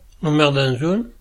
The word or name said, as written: Merdanson